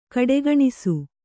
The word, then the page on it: ಖೋಡಿಗಾಣಿಸು: kannada